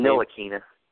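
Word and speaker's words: Ntilikina: Nilakina. – Nilakina, yeah.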